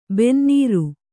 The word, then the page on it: ಬೆನ್ನೇರು: benneēru